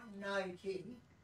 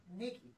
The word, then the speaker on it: Nike: Not Nike, Nicky. – Yeah, Nicky. True. Because the way it's spelt.